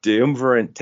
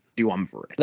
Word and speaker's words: Duumvirate: The Dynamite Dunsumberland. I don't know how to pronounce that word. Tournament? Four? – It was the Dynamite Duumvirate Tag Team Title Tournament. – Duumvirate.